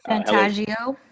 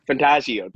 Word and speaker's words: Fantagio: So it kind of brings it back to when Pletus and Fantagio or Fantagaya, I forgot how to pronounce their name, but the ones who had Fantagio. – Fantagio, thank you.